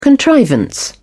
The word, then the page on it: contrivance: British English